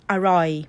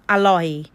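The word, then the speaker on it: aroy (อร่อย): Aroy mean delicious. So aroy need to be R in the way. It's not aloy,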